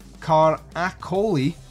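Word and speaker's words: Caracciolo: Franco Cagliocci, or no, that's not even how you pronounce that, Franco Caracoli, let's try that, The Gay Prince, original trailer, and a reversible sleeve with newly commissioned artwork by Simon Pritchard and the original Italian poster art.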